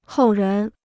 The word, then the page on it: 后人: hòu rén